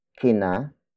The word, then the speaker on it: kina: Narito kina Raja ang mga sapatos ninyo.